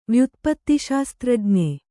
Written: ವ್ಯುತ್ಪತ್ತಿಶಾಸ್ತ್ರಜ್ಞೆ

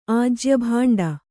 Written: ಆಜ್ಯಭಾಂಡ

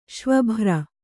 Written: ಶ್ವಭ್ರ

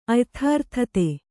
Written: ಅಯಥಾರ್ಥತೆ

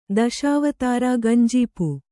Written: ದಶಾವತಾರ ಗಂಜೀಪು

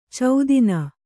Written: ಛೇದನ

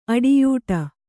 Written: ಅಡಿಯೂಡು

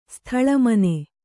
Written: ಸ್ಥಳಮನೆ

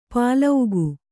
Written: ಪಾಲೌಗು

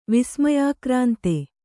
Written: ವಿಸ್ಮಯಾಕ್ರಾಂತ

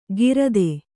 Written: ಗಿರದೆ

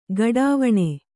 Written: ಘಡಾವಣೆ

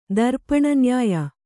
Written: ದರ್ಪಣನ್ಯಾಯ

